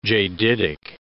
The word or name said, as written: jaditic